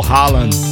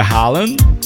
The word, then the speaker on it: Haaland: É, aqui a gente pronuncia Haaland e segundo a mídia lá é R-O-L-A.